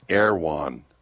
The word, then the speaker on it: Erewhon: It's spelled Erewhon. So we were trying to figure out what the heck is that. They pronounce it Erewhon.